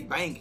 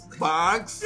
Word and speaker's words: Banks: And you can't say bank. You gotta say it like the Guyana. – Banks.